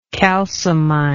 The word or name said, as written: calciminer